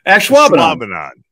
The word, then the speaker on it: Ashwaubenon: Ashwaubenon.